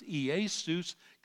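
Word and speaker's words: Iesus (Ἰησοῦς): And now in Greek, it's pronounced Iesus, comes down to us through our language because of the influence, because we got J's and V's in our alphabet. We pronounce his name Jesus in the Greek, Iesus.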